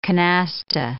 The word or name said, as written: canasta